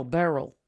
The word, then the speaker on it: Beryl: Then Burl, B-E-R-Y-L. – Burl. – Like Burl. – Yeah. – No, see? – Girl's name, Burl. – Like Milton Burl? – No, B-E-R-Y-L, Burl.